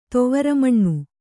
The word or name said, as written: ತುವರಮಣ್ಣು